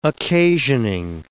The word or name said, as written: occasioning